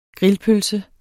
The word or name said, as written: grillpølse